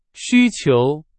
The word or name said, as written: 需求